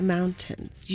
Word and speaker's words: mountains: And since you come from a place that's not here, we all know where directions are because of the mountains. You just look to the mountains and you know that's west, always. – Mountains, you're really pronouncing the T in that, and that's okay.